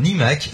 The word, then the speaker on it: iMac: L'iMac, c'est un iMac, hein, et un iMac, c'est justement ce, euh, ce E-Mac, parce que E-Mac à dire, c'est une horreur. – Euh, donc, iMac, mais c'est comme ça que ça se prononce en plus.